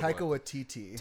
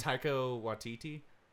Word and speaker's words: Taika Waititi: Taika Waititi. – Taika Waititi.